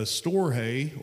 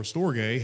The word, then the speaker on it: storge: It's agape, phileo, I think it's storge is how you pronounce it. It's storge.